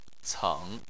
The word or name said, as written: ceng3